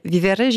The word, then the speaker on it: Vivere: Je le prononce très mal, ce nom, mais Vivere, j'imagine. Donc, comme pour dire vivre. – Oui, c'est exactement ça.